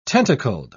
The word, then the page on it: tentacled: British English